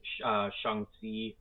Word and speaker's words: Shang-Chi: Shang-Chi, that's how you pronounce it correctly, Shang-Chi. They talk about this in the movie. It's not just Shang-Chi, it's Shang-Chi.